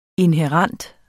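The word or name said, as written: INHÆRENT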